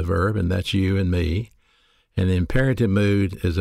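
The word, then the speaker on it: me (μή): Along with the word judge, we have a Greek particle pronounced me, M-E.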